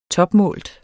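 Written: TOPMÅLT

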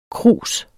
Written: krus

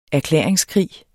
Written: erklæringskrig

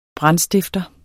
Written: BRANDSTIFTER

